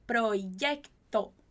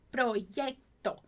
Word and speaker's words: proyecto: Só muito cuidado com a pronúncia de proyecto, proyecto, certo?